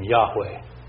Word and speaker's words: Yahweh: YHWH, which is, we then pronounce the name Yahweh.